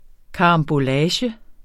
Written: karambolage

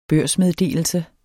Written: børsmeddelelse

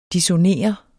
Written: dissonere

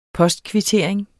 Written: postkvittering

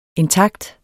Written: intakt

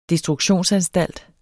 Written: destruktionsanstalt

